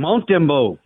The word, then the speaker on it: Montembeau: And I don't know if I'm pronouncing the goalie's name correctly for Montreal. Sam Montembeau? Is that it? – Montembeau. – Okay, Montembeau.